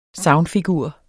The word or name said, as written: sagnfigur